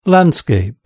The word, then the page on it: landscape: British English